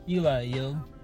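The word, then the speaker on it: Ely.io: It's Eli, yo. That's how I pronounce it. That's how it should be pronounced. Eli, yo.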